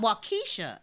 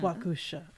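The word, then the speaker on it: Waukesha: It's pronounced Wakusha. Wakusha. Wakusha, not Wakisha.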